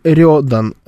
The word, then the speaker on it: Рёдан: Значит, ЧВК, как правильно, Рюдан или Рёдан? Тут интересная история, это же японский. – Да. – И тут получается не на Ё ударение, но там буква Ё. То есть это Рёдан.